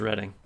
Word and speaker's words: Reading: It's pronounced Reading.